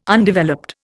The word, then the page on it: Undeveloped: Indian